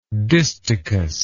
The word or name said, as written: distichously